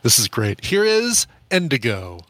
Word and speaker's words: NDGO: They perform as Indigo, but it's spelled N-D-G-O, 4 letters, N-D-G-O, but pronounced Indigo.